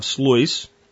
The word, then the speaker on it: Sluis: As the French fleet assembles into the harbor near the town of Sluis, God, I pronounced that right, and he has the phonetics right after it.